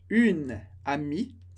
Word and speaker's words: amie: Se eu fosse falar uma amiga, eu diria une amie, acrescentando um e, mas a pronúncia não muda.